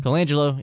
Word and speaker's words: Colangelo: Colangelo is the managing director, which told Melo, "No, we don't want you on the team," and he will forever be my hero.